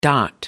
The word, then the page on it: dot: American English